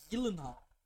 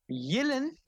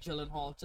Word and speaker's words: Gyllenhaal: Jake Gyllenhaal. – Yillin? – No, it's just Jake Gyllenhaal. Just be as white as you can.